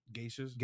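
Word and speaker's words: geisha: Geishas? – Geishas. Is that, that's how you pronounce that word. – Yeah, they wear kimonos.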